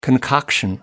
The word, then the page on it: concoction: American English